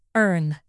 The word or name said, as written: urn